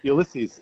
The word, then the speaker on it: Ulysses: Ulysses is Latin for No, you said it right.